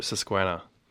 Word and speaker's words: Susquehanna: And you just heard O Susquehanna.